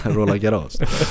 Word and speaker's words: Roland Garros: Jag tror fransmännen själva säger Roland Garros. – Roland Garros.